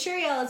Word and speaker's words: Cheriel: But anyways, so my name's Sheryl. It's spelled C-H-E-R-I-E-L.